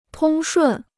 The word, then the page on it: 通顺: tōng shùn